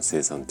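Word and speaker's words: 青酸: あのわらびの中には、生産っていう、こう、栄養素っていうか、うんと、素材っていうか、まあ、要素が含まれてるんですよね。生産は、あの、生産カリの生産って読みます。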